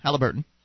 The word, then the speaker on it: Halliburton: But but still, anyway, I saw this documentary on is it pronounced Halliburton? – Yeah, that's about right. Mm-hmm. Halliburton.